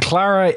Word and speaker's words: Klara: And Klara, litheads, just so you have a clear vision in your mind of this, it's spelled K-L-A-R-A, not C-L-A-R. It's Klara. It's kind of like good. A Germany type of thing. – Yeah, Klara. – And S-U-N or S-O-N? – And the sun, yes.